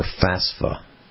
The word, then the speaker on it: FAFSA: You know, college students who are looking for financial aid will sign up for FAFSA.